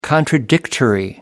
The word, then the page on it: contradictory: American English